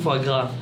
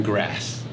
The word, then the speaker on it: foie gras: 第二点是什么？The second one is foie gras. Is it a kind of grass? – Foie gras. – Foie gras. – Foie gras. If there's an S, 如果有 S 的话，不要讲。其实就是怕的时候就不要讲。 – 所以它不是念成 grass。